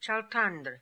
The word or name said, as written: Chaltandr